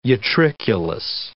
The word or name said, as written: utriculus